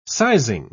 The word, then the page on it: sizing: British English